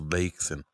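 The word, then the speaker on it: Bleigießen: El bleigen y luego la B rara en. O sea, bleigen. Total que se, es vertido de plomo. La B rara se pronuncia como S. tú entonces Bleichen.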